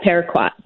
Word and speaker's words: Paraquat: This one that you're working on is involving Paraquat. Am I saying that right? – Yeah, Paraquat.